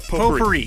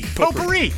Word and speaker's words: potpourri: You take out the T is silent. – Yeah. Yeah. – Potpourri. – Potpourri. Yeah, you got it.